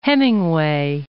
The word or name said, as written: Hemingway